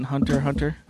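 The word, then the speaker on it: Hunter × Hunter: So do you pronounce the X in Hunter Hunter? – I do not.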